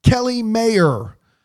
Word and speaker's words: Kelly Mayer: Kelly Mayer, like John Mayer, spelled the same way.